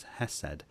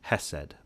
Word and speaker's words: hesed: hesed.